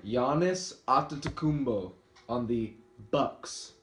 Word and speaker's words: Antetokounmpo: Antetokounmpo. Yeah, I think that's how it's pronounced. – No, Antetokounmpo.